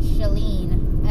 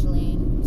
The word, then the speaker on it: Schelin: My Twitter, oh, it's Allison, A-L-I-S-O-N, Shaleen, S-C-H, like, school, E-L-I-N. I have a terrible name, like, in terms of searching for it. It's really hard to pronounce, and, like, from the spelling, no one knows. But, yeah, S-C-H-E-L-I-N is Shaleen.